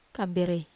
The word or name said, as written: kamberei